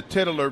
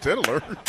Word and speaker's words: titular: And I said, it says, yes, Jared Leto plays the titular villain. – Titular. – Yeah, I said it wrong. – Those sound nearly as villainous. – He looked at me and he said, Tiddler.